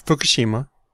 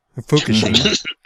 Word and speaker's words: Fukushima: No, Fukushima. – Fukushima. – I know it might sound a little different over there, but Fukushima.